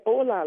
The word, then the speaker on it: Ola: Yes, Ola. Like, hello.